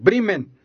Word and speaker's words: Bremen: That's how we pronounce it. Bremen.